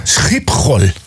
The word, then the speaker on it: Schiphol: Vet du hur man uttalar flygplatsen? – Ja, det låter som en harkling. – Schiphol.